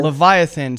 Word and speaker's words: Leviathan: Is that how you say it? – Oh, Leviathan. – Leviathan.